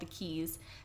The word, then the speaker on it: Quays: And then my last recommendation for that nightlife, it's called The Quays. It's actually spelled Q-U-A-Y.